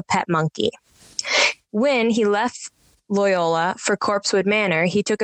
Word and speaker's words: Loyola: Loyola.